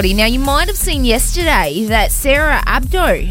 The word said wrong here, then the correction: Abo: has resigned now from the Today Show. – Oh, Sarah Abo, yes. – Is that how you say it? – Yeah, I think it's Abo.